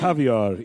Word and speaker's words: Javier: It's Javier. It rhymes with caviar.